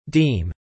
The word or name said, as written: deem